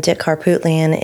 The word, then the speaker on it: Harpootlian: And Dick Harpootlian is the defense attorney and Alan Wilson is the district attorney.